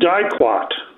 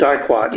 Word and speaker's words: diquat: It's pronounced diquat. So it's diquat, okay.